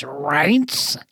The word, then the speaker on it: Reince: How do you pronounce that? Uh, it's Reince.